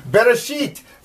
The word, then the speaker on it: Bereshith: Bereshith, the first word. Remember, we're reading from right to left. You can sound that out. Beit, ba, resh, r, aleph. They actually say it's a silent letter, and you don't...